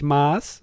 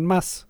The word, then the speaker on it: Maß: Man sagt nicht Maß, sondern Maß. Also mit Doppel-S quasi wird das ausgesprochen.